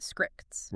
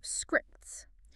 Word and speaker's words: Skrixx: So it is spelled S-K-R-I-X-X, but it is actually pronounced scripts. – Right, yeah. – It rhymes with the word stricts. So scripts.